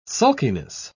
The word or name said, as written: sulkiness